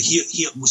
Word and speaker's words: Gila: It's called the Gila River. And it's after the Gila, spelled with a G, Gila River Indians.